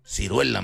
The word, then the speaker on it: ciruela: No se dice Sirguela, mano. – ¿Cómo se dice entonces? – Ciruela, mano.